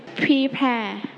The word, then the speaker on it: prepare: Prepare. – Prepare. Let's check together. Emma say it together. Prepare. – Prepare. – Prepare.Okay, so we stretch on the second syllable.